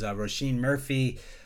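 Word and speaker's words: Róisín Murphy: Before that was Roisin Murphy. I probably say it wrong every time. Overpowered. – You got it right.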